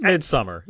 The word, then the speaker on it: Midsommar: I don't know if it's Midsommar.